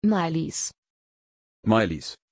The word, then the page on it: Mailies: German